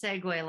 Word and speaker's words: segue: No, it's segue like the awesome little scooter thing.